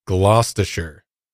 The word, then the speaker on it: Gloucestershire: I looked this Gloucestershire. – Up. Yes. Gloucestershire. – Don't say Gloucestershire. Gloucestershire. Yeah. Don't say Shire, man. As much as you want to say Shire.